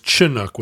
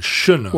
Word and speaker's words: Chinook: And it is The accepted pronunciation now is Chinook with a hard ch. Is, because that's maybe more authentic to the original language and pronunciation. – But of course, as we were raised here in the Northwest, we were raised to pronounce it Chinook.